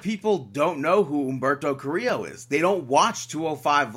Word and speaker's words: Carrillo: Next up, you had Universal Champion Seth Rollins pick up the victory over Humberto Carrillo. – Oh, wow. You pronounced it right this time.